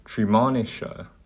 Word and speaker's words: Treemonisha: In 1911, he published Tri- Trimonshina, uh, sorry, this is a weird name to pronounce, Treemonisha, sorry, the opera.